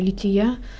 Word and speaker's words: Лития: Часто люди путают, что такое панихида, что такое лития, когда они служатся. Расскажите, пожалуйста. – Ну, тут большой разницы нет. Лития, так вот ударение правильно делать, это моление, это, как правило, часть и отпевания, часть и панихиды, такой как бы сокращенный вариант, что ли, заупокойной службы, который совершается.